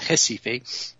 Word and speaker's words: Recife: they're all neuropediatricians in what I call Recife. Apparently the Brazilians pronounce it Recife. I'm going to stick with Recife on the map, northeast Brazil.